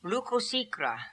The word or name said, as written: Lookosikra